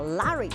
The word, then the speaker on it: Larry: 但是呢，这一位赖瑞啊，那英国发音叫 Larry，他在这个唐宁街十号呢，就待了很唐宁府十号待了好久啊，见证了风云变幻。